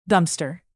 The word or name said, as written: dumpster